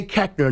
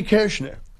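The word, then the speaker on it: Koechner: But they say Kechner. Nowhere in the English language does the O-E make the short E sound.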